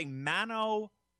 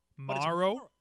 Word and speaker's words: Mauro: I always want to say Mano Ranallo. – Let's sound it out here. Maro. – Maro. – Ranallo. – Ranallo. – There you go. You got it. – Maro.